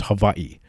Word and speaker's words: Hawaii: Hawaii. – Well, typically you're supposed to, the W is actually pronounced like a V, so it's Hawaii.